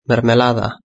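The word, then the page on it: mermelada: European Spanish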